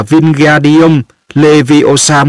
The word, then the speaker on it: Wingardium Leviosa: Ở bàn bên cạnh, Ron cũng không may mắn gì hơn, nó vung vẩy cánh tay như cánh quạt gió, đọc to: Wingardium Leviosa. Harry nghe Hermione cào nhau: Bạn đọc sai rồi, bạn phải đọc là Wingardium Leviosa mới đúng, kéo dài chữ ga ra một cách rất là duyên dáng.